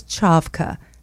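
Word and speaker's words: Čavka: They can go on my website, which is my name, SusanCavka, Susan Kavka. It's Chavka. You can't right. see the accent on the C.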